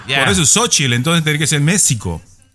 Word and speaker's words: México: Pero yo siempre me he preguntado por qué eh, pronuncian México con J cuando es con X y ahí no me han sabido responder algunos de ustedes.